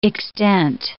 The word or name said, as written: extent